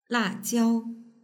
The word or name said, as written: lajiao